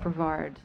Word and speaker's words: Brevard: Brevard.